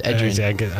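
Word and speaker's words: Edgerrin: Ed Green James, now a Hall of Famer. – How did you say his first name? – Ed Green. – Ed Green. That was Edrin.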